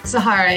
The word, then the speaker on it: Sahara: Did I say that right? – Sahara, yeah. – Sahara. For so long, I mean, I've followed you online, and for the life of me, I thought it was Sarah.